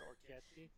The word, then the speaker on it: orecchiette: You could also use like medium shells if you can't find orecchiette, but if you can find orecchiette. – Is that how you pronounce it, or are you putting extra syllables in there? – No, you don't. – Is it not orchetti? – You don't know how to read.